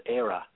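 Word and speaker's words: Éire: The word for Ireland in the Irish language is Eire, which is spelled E-I-R-E.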